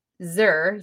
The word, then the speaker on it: zir: Object, zir, Z-I-R. Rhymes with sir, but zir.